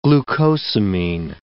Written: glucosamine

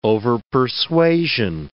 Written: overpersuasion

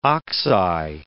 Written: oxeye

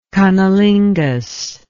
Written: cunnilingus

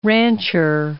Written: rancher

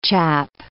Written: chap

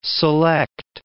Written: select

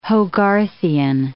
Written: Hogarthian

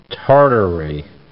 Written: Tartary

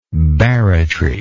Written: barratrously

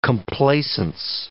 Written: complacence

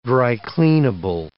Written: dry-cleanable

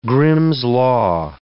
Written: Grimm's law

